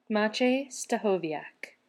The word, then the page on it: Maciej Stachowiak: American English